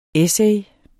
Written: essay

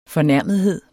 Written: fornærmethed

